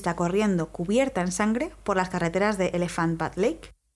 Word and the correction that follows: Elephant Butte Lake: No se dice se dice, perdón, Elephant Butte Lake, que es una zona adyacente a TIC.